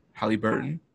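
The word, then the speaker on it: Haliburton: Tyrese Killsworth? I don't know. – No, Halle. – Hollyworth? I don't know. – Halliburton? Halliburton.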